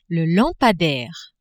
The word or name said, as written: lampadaire